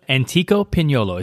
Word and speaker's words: Pignolo: And the menu here at, okay, help me say this right, Antico Pignolo. It's P-I-G-N-O-L-O, Pignolo.